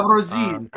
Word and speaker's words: Abruzzese: uh was it how do you pronounce his name? Abruzzi, uh, Abruzzese. – Nick Abruzzese.